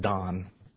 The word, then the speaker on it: Dan: And the other he put in Dan, or actually pronounced Don.